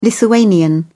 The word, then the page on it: lithuanian: British English